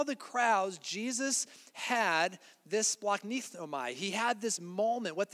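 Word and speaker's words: splachnignomai (σπλαγχνίζομαι): It's splachnignomai. That's how you say it.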